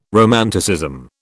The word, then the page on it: romanticism: British English